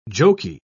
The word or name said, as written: jokey